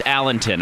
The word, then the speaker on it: Allington: There's no W, it's just Allington. I mispronounced it.